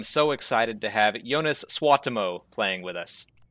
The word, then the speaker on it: Suotamo: I mean, it's Suotamo, but it's yeah, that's Suotamo. In Finnish, every name is pronounced as it's spelled every time the same way.